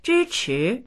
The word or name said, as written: zhi1chi2